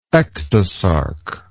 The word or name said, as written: ectosarcous